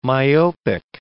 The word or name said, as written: myopic